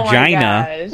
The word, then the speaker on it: Regina: Because Regina rhymes with vagina, fun.